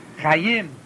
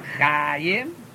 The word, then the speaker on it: Chayim: In general, all accentation of any word is on the last syllable unless it's on next to the last syllable, for whatever reason may be. Chayim. It's not Chayim. It's Chayim. It's not Adoloi, It's Adoloi. It's on the nun of the Shem Hashem.